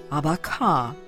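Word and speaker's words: abaca: Abaka, accent on the last syllable, fiber from leaf stalk of banana, commonly used in Philippines for making cordage, coarse fabric, straw hats, native name for Manila hemp.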